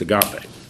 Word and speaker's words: agape: it's not agape, it's agape, is how it's pronounced.